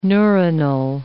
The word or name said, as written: neuronal